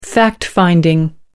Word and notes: fact finding: American English

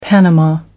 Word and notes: panama: American English

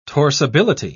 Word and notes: torsibility: British English

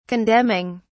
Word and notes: condemning: British English

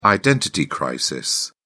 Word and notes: identity crisis: British English

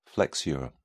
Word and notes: flexure: British English

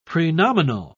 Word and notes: prenominal: British English

